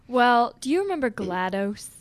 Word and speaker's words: GLaDOS: But it's spelled with an O. Glados.